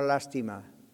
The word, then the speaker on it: lástima: Entonces, sentir lástima no está mal, pero si ustedes conocen el español, hay dos formas de escribir la palabra lástima. Lástima, como la estoy usando ahora, va con acento en la primera A. Lástima.